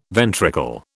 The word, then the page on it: ventricle: British English